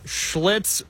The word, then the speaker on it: Schlitz: We had to pour one out for Schlitz Beer.